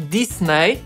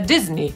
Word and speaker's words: Disney: Ale raczej chodzi mi o to, żebyście się nie zdziwili, kiedy ktoś zamiast Disney, co brzmi dla nas normalnie, powie Disney, co może wam zabrzmieć dziwnie. A to właściwie jest ta poprawna wymowa i tak sam pan Disney się przedstawiał.